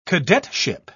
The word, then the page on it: cadetship: British English